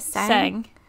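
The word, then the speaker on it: Tseng: Seng. Yeah. – And Elena, that's his partner, isn't it? – Is Elena in Seven? – I think so. Yeah, because Reno and Rude are pairs, and then Seng and Elena are pairs.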